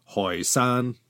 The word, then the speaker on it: Hoysan: The Toisan people in their dialect used an H instead of a T and called themselves in their ancestral homeland Hoysan.